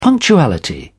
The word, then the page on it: punctuality: British English